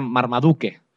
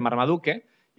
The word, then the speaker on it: Marmaduke: que son la familia dueños de este Marmaduke. Y bueno, que yo supongo que la pronunciación tendría que ser Marmaduke.